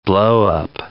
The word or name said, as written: blow-up